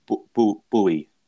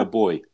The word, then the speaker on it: buoy: They pronounce that buoy. – Boy, yeah. – You know those things that bob up and down in the water. How do you say that? B-O-U. – Boy. A buoy? – A buoy, yeah, you see. – Yeah. – But we call them a boy.